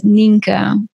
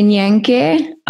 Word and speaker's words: Nienke: And how do you pronounce your name? Is it Nyenke? – It's so funny. It's with everyone I have this, but I also ask the question back. So my name is pronounced as Ninka. – Ninka, okay. All right, okay. – But it sounds like if you read it, a lot of people would say Nyenke.